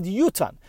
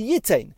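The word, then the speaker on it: yutan (יֻתַּן): We have a tradition from Har Sinai that it's read yutan. The most simple way to read yud taf nun would be yitain, but we read it yutan.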